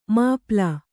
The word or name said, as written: ಮಾಪಾಳ